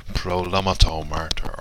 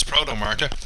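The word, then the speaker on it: Protomartyr: I always have to be careful when I say Proto Martyr because I always feel there should be another syllable in there, like Prolomato Martyr or Protomaton Martyr. It's not, it's Proto Martyr.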